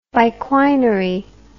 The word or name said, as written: biquinary